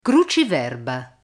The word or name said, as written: cruciverba